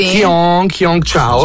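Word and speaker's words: Cheung Chau: Ma si pronuncia veramente così? – Qiong Chao. – Qiong Chao, sì.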